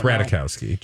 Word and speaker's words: Ratajkowski: It's Ratajkowski. Just say Ratajkowski.